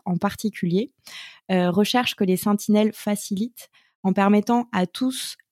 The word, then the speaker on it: Sentinelles: C'est via le biais d'une plateforme qui s'appelle les Sentinelles, donc à bien prononcer avec « saint » et pas « sentinelle », mais il y a un petit jeu de mots.